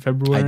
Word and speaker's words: February: Do you pronounce that first R in February? – I do. – So do I. – So I didn't used to when I was a kid, but then I thought that R is there and no one, there's been no official declaration that the R is silent. So I'm just going to go ahead and say it.